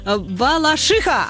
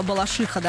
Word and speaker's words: Балашиха: Я же правильно сказала Балашиха, да? Вы же говорите Балашиха. Да, да, раньше было Балашиха, раньше, давным-давно, в 20-х годах прошлого века. А сейчас вот так вот все.